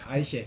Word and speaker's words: Eiche: Is that, am I pronouncing that correctly? German? Eiche. Eiche. – Eiche.